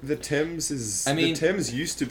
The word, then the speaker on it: Thames: I believe it's pronounced Thames.